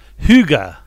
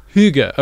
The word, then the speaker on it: hygge: Hygge. – Hygge. Okay, there we go. – It's sort of like a horn on Chitty Chitty Banga.